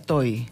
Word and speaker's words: Tohi: T-O-H-I. ¿Y? Toy. – Toy. – Se pronuncia Toy.